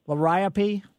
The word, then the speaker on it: liriope: How do you pronounce, Leary rope? – Yeah, liriope. – Liriope, okay, thank you. I didn't know.